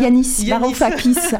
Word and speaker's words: Yanis Varoufakis: Il n'y a pas très longtemps, j'ai écorché son nom, Yakis Varoufakis, c'est ça? Yanis Varoufakis,